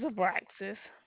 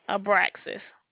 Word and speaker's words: Abraxas: Abraxas. – Oh, how do you spell that? – A as in apple, B as in boy, R A, X as in x-ray, I as in Sam.